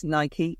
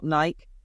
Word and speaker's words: Nike: Top of the list, Nike, which is Nike, not Nike.